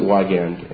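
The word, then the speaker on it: Wiegand: Now, some of you may pronounce it WIGAND, WIGAND, WAGAND. I pronounce it WIGAND, and for me, that's correct.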